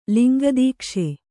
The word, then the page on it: ಲಿಂಗದೀಕ್ಷೆ: kannada